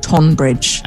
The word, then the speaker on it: Tonbridge: No, people who are really from Tunbridge Wells don't pronounce Tunbridge. They call it Tonbridge, because that's how it's spelled.